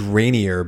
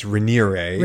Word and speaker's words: Raniere: I always thought it was Rainier, but it's Rainiere.